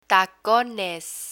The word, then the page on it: tacones: Spanish